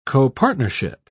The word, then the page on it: copartnership: British English